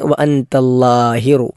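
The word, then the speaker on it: വഅന്തല്ലാഹിറു (وأنت الظاهر): 1 വഅന്തല്ലാഹിറു, അല്ലാ എന്നത് നാവ് മുൻപല്ലിൻ്റെ മുകളിൽ തട്ടുന്ന രൂപത്തിൽ ലാ ആണ് ഉച്ചരിക്കേണ്ടത്. ലാഹിറു എന്ന ഹാ മലയാളത്തിലും ഇംഗ്ലീഷിലും ഒക്കെ സമാനമായ അക്ഷരമുള്ളത് കൊണ്ട് പല ആളുകളും തെറ്റിക്കാറില്ല. ഫലൈസ ഫൗഖക, അവിടെ ഫൗഖക എന്നതിൽ ഫൗഖക രണ്ടും 2 കാഫുകളാണ്.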